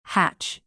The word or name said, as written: hatch